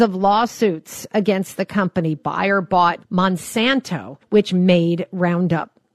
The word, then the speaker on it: Bayer: It's a German company. They're soaring. We pronounce it Bayer here.